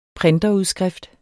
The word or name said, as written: printerudskrift